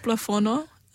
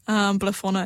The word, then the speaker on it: Blafono: What is it called? – I called it Blafono, Blafono in Ghana,